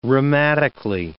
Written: rheumatically

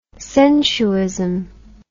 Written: sensuism

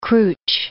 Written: Krutch

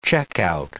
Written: checkout

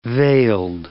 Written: veiled

